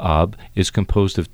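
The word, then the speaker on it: Ab (אב): Ab. – Perfect. Ab or Av, the B or V sound is either one.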